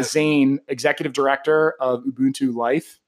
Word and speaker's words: Ubuntu: Ubuntu. You said it. – I got it. I even wrote literally here, it's phonetically on my notepad. No one can see. – Let's see I did Ubuntu.